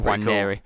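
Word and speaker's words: Nwaneri: Yeah, no, I did do some homework on how to pronounce the name, because it's a silent N, I think he must be from African heritage, but he's an English, actually, he's played for England youth levels, so silent N, Wanneri.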